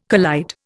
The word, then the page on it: collide: Indian